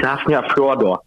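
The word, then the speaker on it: Hafnarfjörður: Wie spricht man den richtig aus? – Ich weiß es auch nicht, hieß Hafnir Fjordur. – War doch so. – Bravo, Willi! Sensationelle Aussprache, muss ich sagen, als Halbschwede. Hat mich überzeugt.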